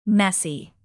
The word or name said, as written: messy